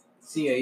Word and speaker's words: Sia: Sia, yeah, facts. Facts. – That's right. Okay, that's how you say her name, Sia.